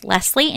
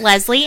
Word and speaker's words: Leslie: My name is pronounced Leslie, and you've said it right all day long, not Leslie.